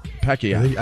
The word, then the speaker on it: Pacquiao: Pacquiao. – I think LeVar's right. – That's how they say it. Pacquiao.